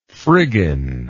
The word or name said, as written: frigging